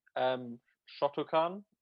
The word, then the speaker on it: Shotokan: So we'll start with Shotokan.